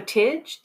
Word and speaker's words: Tiges: It's T-I-G-E-S. So Tidge, the winemaker. T-I-G-E-S, the winemaker.